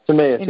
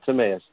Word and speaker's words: Timaeus: Timaeus. Yeah, that's how we say it. Timaeus.